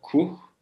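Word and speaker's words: Kûh: ismi Kuh ya da Kuh K-U-H ama U'nun şapkası var. Artık nasıl telaffuz ediyor? Kuh.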